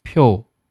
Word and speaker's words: 표: 好，下一个单词呢是票，还有呢是 표， 표，发音呢特别像，对吧？